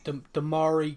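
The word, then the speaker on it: Demarai: You look at Demiro Gray. How do you pronounce it? – Demari Gray. – That's the one.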